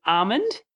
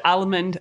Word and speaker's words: almond: I used to always pronounce the L a lot, and so almond. I used to say it all the time. I couldn't get it right. – Almond. – Almond. – It's a silent L. It's a silent L. Almond. Yeah, that is kind of an odd.